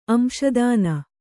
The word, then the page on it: ಅಂಶದಾನ: Kannada